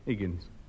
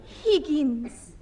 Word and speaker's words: Higgins: Percival Higgins. H, hi, egg, egg, hi, hen, yes. – Higgins. – No, Higgins. No, the H is silent as in orse.